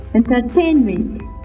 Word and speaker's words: entertainment: C-H-E-M-I-S-T-R-Y. Chemistry به معنای کیمیا. The next word, لغت بعدی. Entertainment. Entertainment. E-N-T-E-R-T-A-N-M-E-N-T. Entertainment. And the stress on the word entertainment is on T-A-I-N. It means on the second syllable. خب استرس یا فشار در لغت انترتینمنت در سلابل یا هجای دومی هستن روی T-A-I-N به قسم تلفظ مهم انترتینمنت انترتینمنت انترتینمنت is noun.